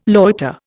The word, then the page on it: loiter: Indian